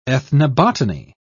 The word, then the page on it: ethnobotany: British English